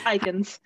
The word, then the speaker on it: Aeikens: Did I say your name right? I probably should have asked before. – It's all good. I get okay. It all, so it's Aeikens.